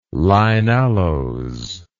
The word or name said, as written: lignaloes